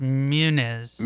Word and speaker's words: Muniz: Be the first event in a part-time NASCAR Xfinity Series schedule for Munoz. Is it Munoz? Is that how you pronounce it? – I think it's Muniz. – Muniz? Okay, M-U-N-I-Z, but I did not know that.